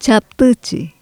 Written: chaptychi

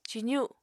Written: cizu

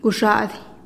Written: guxa'adih

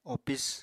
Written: opis

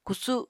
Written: kusu

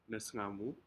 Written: nesgaamu

